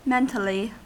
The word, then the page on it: mentally: American English